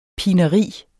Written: pineri

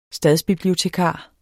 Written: stadsbibliotekar

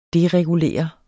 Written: deregulere

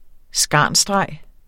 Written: skarnsstreg

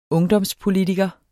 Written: ungdomspolitiker

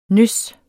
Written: nys

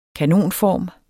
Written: kanonform

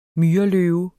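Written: MYRELØVE

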